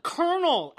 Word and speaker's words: colonel: Just Like, how do you get kernel out of that? – Exactly. – It is colonel. – Exactly. – You pronounce it colonel.